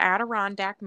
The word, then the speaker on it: Adirondack: Once upon a time in the Adirondack Mountains. Hope I said that right. – Mama. I think you did.